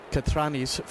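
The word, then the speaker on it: Katranis: Katrinis, forgive me, the Greek pronunciation I'm going for here tonight.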